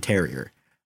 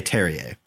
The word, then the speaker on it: Terrier: Rennes also had the second highest goal differential and second highest goals scored, powered a lot by Martin Terrier, or should I say Terrier, because that's how it would be pronounced, because French.